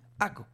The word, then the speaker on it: Akka: Se escribe A-K-K-A. Aco.